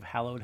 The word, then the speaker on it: hallowed: Correct pronunciation of hallowed has three syllables.